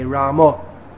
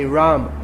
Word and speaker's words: Ram: They say Ramo, Hare Ramo. In the Hindi-speaking area, they'll just say Ram. In Gujarati also, Ram. It's not Rama or Ramo. It's Ram. Hare Ram, Hare Ram, Ram Ram, Hare Ram.